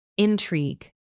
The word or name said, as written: intrigue